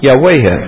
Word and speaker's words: Yahweh: And it probably was pronounced, we don't know how this word was pronounced in the Hebrew Bible, but this word, which occurs something like 5,000 to 6,000 times, was probably pronounced Yahweh. It probably had an A here and an E here.